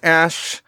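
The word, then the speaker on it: Æ: I mean, it's just X, the letter X, and the AE is pronounced Ash.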